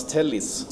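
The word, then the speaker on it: Telles: Nej, Tellis, det, vi, vi, vi, googlade upp det korrekta uttalet.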